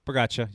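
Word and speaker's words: Pogačar: But Tadej Pogacar also did very well, and he's in the lead. Did I butcher it? – Pogacar.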